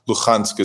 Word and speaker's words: Luhansk: Luhansk is more of a Ukrainian pronunciation than Russian.